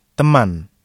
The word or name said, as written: teman